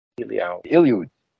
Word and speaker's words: Elioud: Oh, Raven, have you ever heard of, I think it's the offspring of a Nephilim, and it's called the Iliad, and you spell that E-L-I-O-U-D. Iliad.